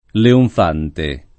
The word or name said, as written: leonfante